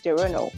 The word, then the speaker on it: Toronto: But here, apparently, T is silent. Second T is silent. So it's Toronto.